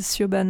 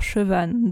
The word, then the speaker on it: Siobhan: Euh, si on le dit phonétiquement, c'est Siobhan Dowd, mais comme elle est irlandaise, évidemment, ça se prononce jamais comme ça s'écrit. Et donc, j'ai écouté la vidéo avant. Ça se dit un truc genre Chevan Dowd,